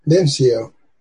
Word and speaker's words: Dencio: So I don't know how to pronounce his last name. D-E-N-C-I-O. – E-E-N-C-I-O? – It's a D-D-E-E-N-C-I-O. D as in David. Echo, Echo. Nancy. Charlie. – Dencio. – Dencio, yeah. Manny Dencio.